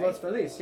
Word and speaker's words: Los Feliz: It's really, people say Los Feliz because Los Angeles for the Los, right. – And then Feliz because that's how someone pronounced it a long time ago.